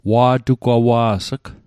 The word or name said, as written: wa'tugwawa's'g